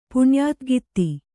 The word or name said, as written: ಪುಣ್ಯಾತಗಿತ್ತಿ